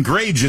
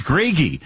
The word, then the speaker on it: Grage: All this time I've pronounced his last name wrong. That Dustin Grage is doing a great job, and probably half my colleagues are like, who are you talking about? It's Gragey. Dustin Gragey, Minnesota-based journalist, columnist at Town Hall, joins us now.